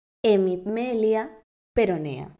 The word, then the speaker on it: hemimelia peronea: Y nació con una malformación congénita llamada hemimelia peronea. ¿Lo dije bien? – Sí.